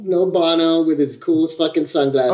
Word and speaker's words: Bono: Yeah, it's Bono, dude.